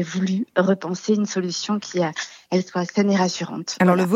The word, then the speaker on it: Elhee: Vous avez créé l'entreprise Elle, qui s'écrit E-L-H-E-E.